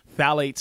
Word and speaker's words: Phthalates: Phthalates. So it's spelled with a P, but the PH is silent.